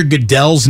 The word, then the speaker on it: Goodell: But to not know Roger Goodell's name is pronounced Goodell, I mean, not Goodell,